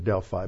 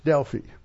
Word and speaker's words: Delphi: Usually people pronounce it Delphi, but it's Delphi.